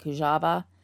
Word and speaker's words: Kujawa: Her stepfather's name was Larry, and the last name was Kujawa. Now, I've also heard it with the W pronounced kind of as a V, like Kujawa, but I'm going to say Laura and Larry for most cases.